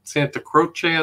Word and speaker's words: Santa Croce: I think you mentioned in your book, they were buried, or the family burial is at the Church of Santa Croce, I think is how it's pronounced. – That's right.